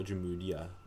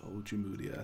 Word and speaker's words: Ojemudia: Mine's Robinson, but I also think Michael Ojemudia, or if that's how you say it, is a little bit interesting. – Ojemudia, I think it is. Sounds okay.